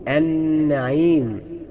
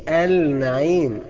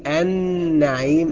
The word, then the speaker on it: النعيم: طيب النون النعيم، ما بقولش النعيم، النعيم كده ما يبقاش نعيم، يبقى خطأ جدا، يبقى لازم نقول النعيم بإدغام اللام في النون.